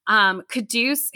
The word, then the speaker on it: Quddus: Caduce, who I had to remember how you pronounced his name because he spells it Q-U-D-D-U-S.